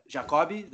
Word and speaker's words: Jacob: Uh, I, I it's funny because in Brazil, people don't say my name that great, so a lot of people call me uh, Jacobi. They try to say Jacob, and that's how they say it. So I don't know if that's really a nickname, but that's how they pronounce my name.